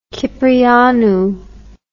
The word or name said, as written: Kyprianou